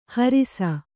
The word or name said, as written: ಹರುಸ